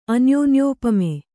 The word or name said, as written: ಅನ್ಯೋನ್ಯೋಪಮೆ